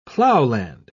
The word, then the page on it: plowland: British English